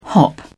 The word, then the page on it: hop: British English